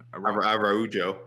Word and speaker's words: Araújo: Araújo. – Araújo.